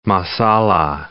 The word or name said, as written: masala